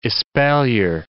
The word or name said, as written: espalier